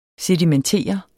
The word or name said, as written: SEDIMENTERET